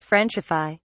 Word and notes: frenchify: British English

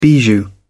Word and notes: bijou: British English

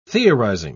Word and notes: theorizing: British English

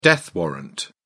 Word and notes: death warrant: British English

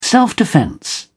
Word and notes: self-defence: British English

